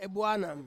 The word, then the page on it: buanam: Ansus